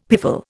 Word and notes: piffle: Indian

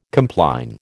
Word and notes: compline: American English

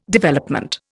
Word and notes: Development: Indian

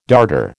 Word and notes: darter: American English

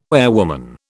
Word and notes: airwoman: British English

